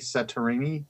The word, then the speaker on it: Satriani: Joe Satterini, Satriani. – Satriani, yeah.